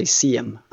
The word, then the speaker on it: SIM: I think you pronounce it SIM if you're... I think it's pronounced SIM in English or American. In Sweden, we say CM.